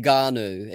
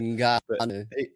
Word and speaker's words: Ngannou: What, how do you say his surname? Ngannou or something? Is that it? Ngannou. It's N-G. Yeah. – Okay. – Ngannou.